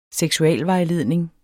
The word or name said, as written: seksualvejledning